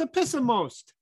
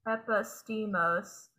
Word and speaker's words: Epistemos: It's Epistemos. Yeah, that's the one. – Epistemos. – No, I probably still butcher it, but I do my best. – I feel like I've been doing it for 2 years, so. – Epistemos. That's how it's written as the pronunciation. Epistemos.